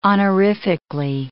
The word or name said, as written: honorifically